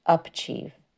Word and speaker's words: UPchieve: remote learning and tutoring through programs like UP Achieve have afforded a way for students to recover credit lost, Rummel said. I'm sorry, I think that's pronounced UPchieve. UPchieve